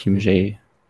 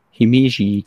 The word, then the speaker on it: Himeji: Himjay Castle. Nee, bestimmt, nee, nicht Himjay. Himeji Castle.